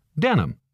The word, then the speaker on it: denim: In English, de Nîmes is pronounced denim, so that's why we call this type of fabric denim.